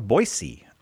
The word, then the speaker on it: Boise: We're going to Boise, Idaho. Despite that being an, intervocabular S. – Intervocalic S. – It's pronounced with a S sound.